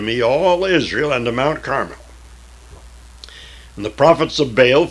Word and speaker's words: Carmel: Carmel, I like to say. Put the emphasis on the L.